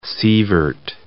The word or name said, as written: sievert